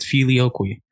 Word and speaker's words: filioque: And, you know, that brings us to the Middle Ages and filioque, which I cannot believe it's said like that. I looked it up, but it's filioque.